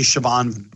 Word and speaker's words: Siobhan: The article's written by, so it's spelled Chauvin, but it's actually Siobhan Brett.